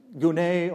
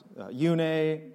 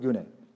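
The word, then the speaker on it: gune (γυνή): So the Greek word for woman is gune, or sometimes pronounced yune or junai. Let's just go with gune.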